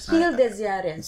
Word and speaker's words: Spiel des Jahres: Spiel des Jahres.